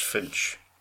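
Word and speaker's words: ffinch: It's just pronounced Finch. – Why is it spelled Polly Finch? – It was just Polly being stupid, that she didn't realise it. You'd pronounced it the same way.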